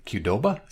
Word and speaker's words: Qdoba: His tweet suggested he pulled the same scam with uh, Qdoba. Is that how you pronounce that? Yeah, Qdoba.